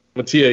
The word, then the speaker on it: Mattia: Mattia, yeah.